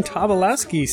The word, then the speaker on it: Tobolowsky: And we are joined tonight by Stephen Tobolowsky. Stephen, did I pronounce that right? – You absolutely did.